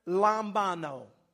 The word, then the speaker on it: lambano: That word received there in that verse is the word lambano. It's spelled L-A-M-B-A-N-O.